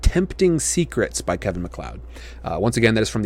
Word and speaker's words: MacLeod: Most often from a composer named Kevin MacLeod. That's how I've seen it. That's how I've heard it pronounced before. MacLeod.